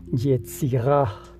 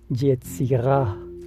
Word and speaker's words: Yetzirah: Y la palabra con, la palabra con la que ellos lo definen es, con Y, con Z y con H al final. Yetzirah. Es una pronunciación un tanto como el francés que es gutural. Yetzirah.